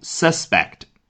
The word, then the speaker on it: suspect: The suspect 啊，注意 suspect 这个词的读音，做名词讲，也就是嫌疑犯，它的重音呢是在开头 suspect。